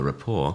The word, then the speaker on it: rapport: I've largely sat at the bar, which has allowed me to develop a rapport, which I say in the same way I'd say restaurant, not pronouncing the T at the end.